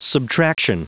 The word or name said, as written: subtraction